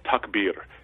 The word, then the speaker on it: takbir: Muslims have a fundamental doctrine called takbir. It's spelled T-A-K-B-I-R, but it basically is the fundamental doctrine of God's greatness.